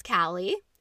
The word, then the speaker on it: Kallie: So, my name is Callie. I've already said that twice, but it is spelled K-A-L-L-I-E, not C-A-L-I or C-A-L-L-I-E, even though that is the way it's pronounced.